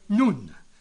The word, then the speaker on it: Nun: But you got Alpha, Bits, Gimel, Delta, He, Vav, and so on, right down to the 13th letter, and the 13th letter is Nun, N-U-N, but pronounced more like Nun. Almost the difference between Nun and Noon. Nun, okay?